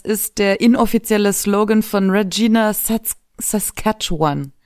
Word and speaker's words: Regina: Regina, mein Fehler. Jedenfalls Ryan Reynolds ist in Schwierigkeiten geraten in Regina. – Ja, weil sonst reimt es sich nicht. – Oh, wow, okay. – Rhymes with fun.